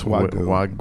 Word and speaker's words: Wagyu: Is that how you pronounce it? – It's Wagyu.